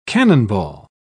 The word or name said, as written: cannonball